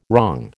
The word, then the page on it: wrong: American English